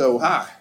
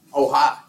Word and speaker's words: Ohio: So I packed up my shit and went to Ohio. – You said it correctly, by the way. – Huh? – You said it correctly, by the way. Ohio.